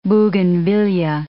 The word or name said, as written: bougainvillaea